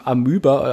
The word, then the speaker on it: Amoeba: Das erste Album äh, wollte ich mir 2009 kurz nach der Erscheinung in San Francisco im Amoeba, äh, Amöba, wie wird das euch ausgesprochen? Amoeba. Amoeba, also das ist so wo man wahrscheinlich jede Platte der Welt kriegen würde.